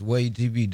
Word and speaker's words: WAYH: Way TV, am I saying it right? – Yes, Way TV, WAYH.